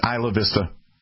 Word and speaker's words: Isla Vista: Is that how you pronounce that? Is it Isla Vista? – Yes.